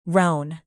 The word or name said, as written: roan